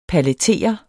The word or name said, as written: PALLETERE